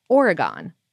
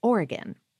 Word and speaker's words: Oregon: They continue, in your most recent episode on masala chai, you noted the brand Oregon Chai, which is delicious, but pronounced it Oregon. The correct pronunciation is Oregon.